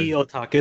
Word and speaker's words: CEO Otaku: How do you pronounce that? I always wonder. – CEO Otaku. It's like CEO, but CEO Otaku. Okay. – Yeah. – The O is double purposed.